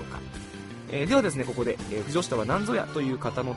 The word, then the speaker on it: 腐女子: 突然ですが皆さん、腐女子という単語、ご存知でしょうか。